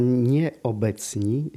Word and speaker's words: Nieobecni: Wystawa Nieobecni, ja tak akcentuję nieprzypadkowo, jako że tu akcent pada na ten człon obecni, bo ciągle są z nami, pomimo że już nie żyją.